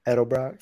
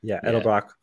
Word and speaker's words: Edelbrock: So 3.5 liter V6 with an Edelbrock, is that how you say it? Supercharger? – Edelbrock. – Edelbrock. 400 horsepower, and 310.